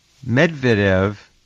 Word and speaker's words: Medvedev: Medvedev, I'll go with the English pronunciation, is one of the people that I'm sure Putin uses to either float concepts or speak for him in some ways.